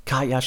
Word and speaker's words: Caya: Oder ihr googelt einfach mal Kaya für Startups. Kaya schreibt man übrigens C-A-Y-A.